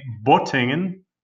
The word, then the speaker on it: Bottingen: His paper trail leads further back to a man with surname Matthies, who was born in a town called Bottingen, B-O-T-T-I-N-G-E-N. He spelled this without an umlaut, so it would be pronounced Bottingen.